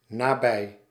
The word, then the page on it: près: [pʁɛ]; France